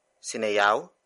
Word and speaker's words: sinayaw: For example, sinayaw ni Chris ang shuffle. Sinayaw ni Chris ang shuffle. Sinayaw ni Chris ang shuffle. It means, Chris danced the shuffle. Word for word, it's sinayaw ni Chris ang shuffle.